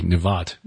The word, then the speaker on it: Nebat: Now, in the 18th year of King Jeroboam, the son of Nebat, which if you're going to actually pronounce it according to Hebrew, it would be Nevat, mm-hmm.